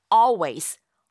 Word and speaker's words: always: 실제 발음할 적에는요. all 하는 그 all, 리을 발음을 아예 하지 않아요. 그래서 always 라고 발음하게 됩니다.